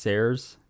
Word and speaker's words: Seres: And there's another collaborator on that called, I think it's pronounced Sares, S-E-R-E-S.